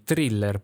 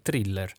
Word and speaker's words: thriller: Poi abbiamo i thriller, ok, noi lo pronunciamo proprio così, thriller, perché noi la H non la pronunciamo, ricordatevi, thriller.